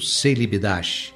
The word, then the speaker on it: Celibidache: que nós pronunciamos aqui no Brasil, Celibidache.